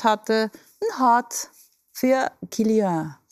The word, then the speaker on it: Kylian: Wir haben Kylian Mbappé. Habe ich das richtig gesagt? – Er ist auf jeden Fall Kylian.